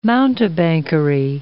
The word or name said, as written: mountebankery